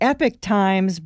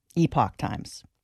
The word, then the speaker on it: Epoch Times: It's the Epic Times, but... That's the American pronunciation. In Europe, it's Epoch Times.